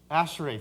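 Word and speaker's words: Ashrei: and I'm going to say it wrong, Asheray. Is that right? Asheray, thank you.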